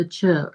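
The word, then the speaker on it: Chöd: It's C H O. It's actually spelled C H O and two little dots over the O, or it's spelled C H O D. The two dots make it chud, um, a light D at the end of it.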